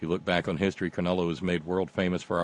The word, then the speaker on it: Cronulla: Cronulla, as like in null and void. Cronulla.